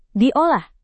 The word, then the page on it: diolah: Indonesian